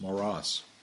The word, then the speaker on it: Maurras: He told me that the S is pronounced at the end of the name. It's Maurras.